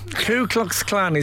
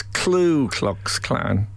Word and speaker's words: Ku Klux Klan: Ku Klux Klan is always Klu Klux Klan.